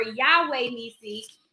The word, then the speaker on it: Yahweh-Nissi: So this morning we're going to talk about Jehovah-Nissi or Yahweh-Nissi or Jehovah-Nissi, depending on how you want to pronounce it.